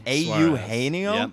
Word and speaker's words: Eugenio: All right, up next we got Eugenio. – Eugenio. – Eugenio? – Yep. – Wow, dude. That's a much cooler way to pronounce it, and I love it.